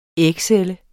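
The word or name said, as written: ægcelle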